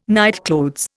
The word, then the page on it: nightclothes: Indian